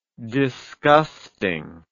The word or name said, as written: disgustingness